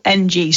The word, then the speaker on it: Ng: Yeah, Ng. Okay. – That's how you say it, I think. – Right, okay. I can never get the names right. – Well, there is no vowels in his surname.